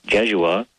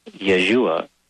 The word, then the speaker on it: Yeshua: A lot of people nowadays call him Jeshua. And that begins with a J. But no, it should be pronounced Yeshua, which was his real name.